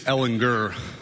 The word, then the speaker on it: Ellinger: I feel like the two older guys, Ellinger, with the hard G.